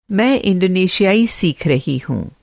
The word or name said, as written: इंडोनेशियाई